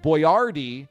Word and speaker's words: Boiardi: And his name isn't B-O-Y-A-R-D-E-E. He changed it because Americans might not have been able to pronounce his normal Boyardee, which is spelled B-O-I-A-R-D-I.